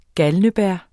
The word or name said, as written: belladonna